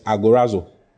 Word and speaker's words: agorazo: The first word is agorazo. Agorazo is spelled A-G-O-R-A-Z-O. Agorazo. Agorazo. A-G-O-R-A-Z-O.